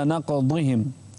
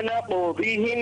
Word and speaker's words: نقضهم: انتبه للضاد. فبما نقضهم. – نعم. – لا تنطقها دال. – نعم. فبما نقضهم